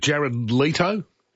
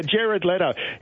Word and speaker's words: Jared Leto: I don't know much about even how to pronounce it. Jared Leto? – It is.